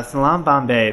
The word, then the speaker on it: Salaam Bombay: And I hope I'm pronouncing this correctly again. Salaam, Bombay. – Yeah, Salaam, Bombay.